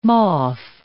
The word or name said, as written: moth